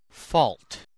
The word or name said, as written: fault